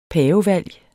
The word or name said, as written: pavevalg